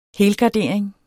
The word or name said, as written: helgardering